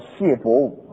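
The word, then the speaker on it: 解伯武: 他讲的时候，我就想到我一个好朋友、好同工，在台湾，一个牧师，他的名字叫谢伯武啊，解那个解释的解念成谢，谢伯武牧师。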